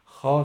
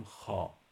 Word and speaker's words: خوا: یعنی ما وقتی تلفظ میکنیم نمیگیم خوا، میگیم خا، پس دو تا واژه داره. وقتی مینویسیم خوا مینویسیم. البته